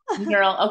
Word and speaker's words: Knerl: No, it's Knerl. – Knerl. Okay. I honestly thought so, but I was just like, I don't know, silent K. I don't know. – Yep, you're right.